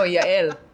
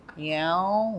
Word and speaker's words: Yael: Yael. I'm like, no, Yael. Yael, like the university. No, Yael. Yael. No. Like, you can't correct them.